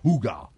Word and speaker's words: hygge: Eh, it's funny because it's spelled H-Y-G-G-E, pero se pronuncia Huga.